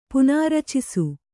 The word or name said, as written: ಪುನಾರಚಿಸು